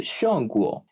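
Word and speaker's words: Shongguo (中国): Para ellos es Shongguo.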